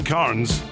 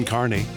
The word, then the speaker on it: Carnes: Kim Carnes, or as producer Amy thought, it was Kim Carney.